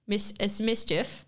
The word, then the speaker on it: MSCHF: So Fenty Beauty recently teamed up with Mischief, but it's spelled M-S-C-H-F, for a campaign where customers could buy a box of products.